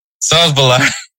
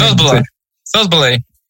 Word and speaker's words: Szoboszlai: No, you said it right the first time. Now you just have to wait a little bit, kind of think it in your mind. – Szoboszlai. – There you go.